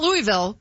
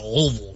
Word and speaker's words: Louisville: We're at Louisville the weekend, not Louisville. – You gotta say it like you have peanut butter stuck in the roof of your mouth. Louisville.